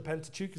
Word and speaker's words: Pentateuch: Children, the Pentateuch is a good word for you to memorize. The Pentateuch, simply, If you think about the word pent, you hear the first syllable there, pent.